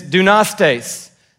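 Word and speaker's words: dunastes (δυνάστης): For example, the word is dunastes. So that's how you say it. You're like, I don't know how to say Greek. It says phonetic spelling right there in the middle. Dunastes, that's how you say this word.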